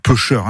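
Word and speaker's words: peuchère: Et outre la forme standard pêcher, il existe une prononciation pêcheur, au pêcheur.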